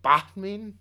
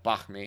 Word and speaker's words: banh mi: Do you pronounce the H in banh mi? Did you say banh mi? – Yeah, banh mi. – I think it's just banh mi. Like it's like basically pronounced like B-O-N-M-E.